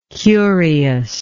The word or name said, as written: curiousness